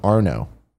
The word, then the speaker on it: Arnaud: It's pronounced Arno, but there's a lot of other letters in there.